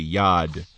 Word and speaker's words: Yad: And now you have the letter Yod, which, by the way, is how you say hand in Hebrew. You say Yad. Yad and Yod are spelled the same way. You just change the vowel sounds.